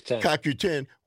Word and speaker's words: CoQ10: CoQ10.